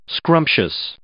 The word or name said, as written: scrumptious